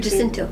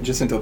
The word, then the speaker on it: Jacinto: Jacinto. – Say it? Jacinto, thank you.